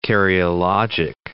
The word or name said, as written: karyologic